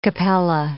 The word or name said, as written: Capella